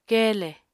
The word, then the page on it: keele: Apatani